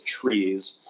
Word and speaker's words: TRIZ: It's spelled in all caps, TRIZ, and I've heard it pronounced as trees or what else.